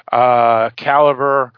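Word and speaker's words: Calibre: Caliber. Is basically how it's pronounced. A lot of films like theater and things of that nature are spelled R-E instead of E-R.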